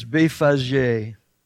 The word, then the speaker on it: Bethphage: And when they drew nigh unto Jerusalem and were come to, and I had to really look up the proper pronunciation of this village. It's Bethphage.